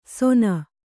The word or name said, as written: ಸೋನ